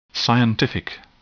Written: scientific

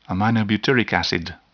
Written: aminobutyric acid

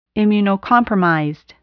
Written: immunocompromised

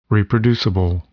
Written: reproducible

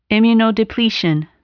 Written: immunodepletion

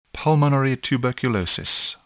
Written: pulmonary tuberculosis

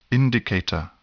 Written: indicator

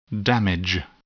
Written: damage

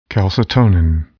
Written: calcitonin

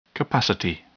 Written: capacity

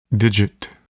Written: digit

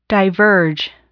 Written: diverge

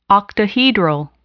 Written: octahedral

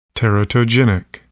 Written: teratogenic

